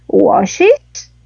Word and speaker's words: washes: Washes. Wash pasa a washes. – Ah, washes. – Claro, okay. Como termina en H, sí, porque wash termina en H, W-A-S-H. Entonces voy a agregar E-S.